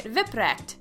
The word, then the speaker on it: Wipprecht: That's VIP, like VIP, and RECT, like WRECKED, phonetically, of course, if you're trying to say it, Wipprecht.